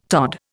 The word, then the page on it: tod: Indian